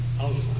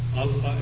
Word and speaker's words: Alpha: And I don't pronounce it as Alpha, really. It's, I'm going to try to pronounce it as much as possible in Greek phonology. So it would be Alpha. Alpha as in father.